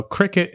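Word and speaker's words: Cricut: I will say that for those of you who don't know, cricket is spelled C-R-I-C-U-T. I did not know that's how you spelled it.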